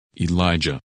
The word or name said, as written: Elijah